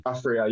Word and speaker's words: Aphria: Aphria, yeah, I know Aphria.